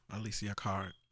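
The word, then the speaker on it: Alessia Cara: Yeah, Alicia Cara.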